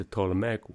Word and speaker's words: Ptolemaic: And that's a Ptolemaic with a silent P.